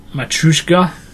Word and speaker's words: Matryoshka: How do you say it? – Matryoshka.